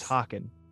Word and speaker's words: Hawken: Oh, my name's Hawken. – Hawken. – Literally spelled like Hawk with E-N at the end.